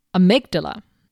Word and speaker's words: amygdala: Enter the amygdala.